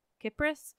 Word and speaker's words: Cypris: And yes, I recognize it's confusing to pronounce the country and island with a soft C, Cyprus, and her name with a hard Cypris.